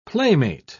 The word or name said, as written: playmate